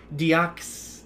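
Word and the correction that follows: Diakiese: it's the it's Mark Jacasey. Jacasey, thank you. You're always good on that name.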